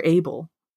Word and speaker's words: Abel: Is it Abel or Abel? – Abel. – Abel, thank you.